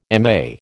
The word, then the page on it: MA: American English